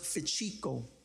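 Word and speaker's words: fichico (ψυχικός): So the word, the Greek word for natural man, it comes from a word that's pronounced fichico. You ever hear the Puerto Ricans always saying, oh, yeah, chico.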